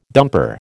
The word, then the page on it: dumper: American English